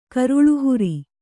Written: ಕರುಳುಹುರಿ

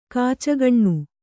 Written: ಖಚಕ್ಕನೆ

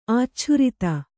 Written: ಆಚ್ಛುರಿತ

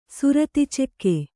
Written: ಸುರತಿಚೆಕ್ಕೆ

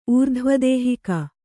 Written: ಊರ್ಧ್ವದೇಹಿಕ

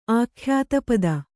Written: ಆಖ್ಯಾತಪದ

